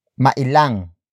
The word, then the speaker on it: mailang: Word for word, it's huwag kang mailang sa kanya.